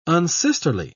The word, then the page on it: unsisterly: British English